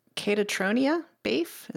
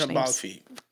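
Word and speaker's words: Caitríona Balfe: Catatronia Bafe, is that? That's nowhere near what it could possibly be. – I'm lost here too. Katrina. I think they pronounce it. It's one of those Irish names. – I always trip up on the Irish names. – Katrina Balfi.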